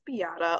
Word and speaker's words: Beata: So as I mentioned before, my name is Beata.